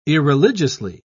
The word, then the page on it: irreligiously: British English